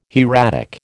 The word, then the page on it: hieratic: American English